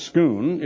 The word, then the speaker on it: Scone: The Stone of Scone, pronounced Scoon,